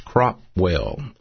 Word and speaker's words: Cropwell: and the city that I live in is pronounced Cropwell, like a crop, Cropwell, Alabama, and our zip code is 35054.